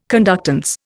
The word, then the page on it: conductance: Indian